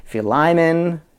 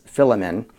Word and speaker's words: Philemon: If I'm saying Philemon a few different ways, I've heard it pronounced so many different ways. Philemon. One of my favorite teachers just says Philemon. Philemon is another teacher online, says it that way.